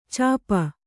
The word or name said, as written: ಚಿಪ್ಪಿ